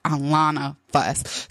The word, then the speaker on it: AlonaFuss: It's called Alana Fuss. So guys, go ahead and follow me on TikTok. And Alana Fuss is spelled A-L-O-N-A-F-U-S-S, like Alana Fuss.